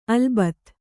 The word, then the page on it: ಅಲ್ಬತ್: Kannada